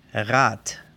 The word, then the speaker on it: Rad: Quiere decir que la B se pronuncia P, la D se pronuncia T y la G como una K, pero solo al final. Un ejemplo, C-L-U-B se lee club. R-A-D que es llanta, rueda, Rat. Y el día T-A-G, TAG, Ven De ahí el sonido rudo o fuerte que ustedes tal vez identifican y asocian con el alemán.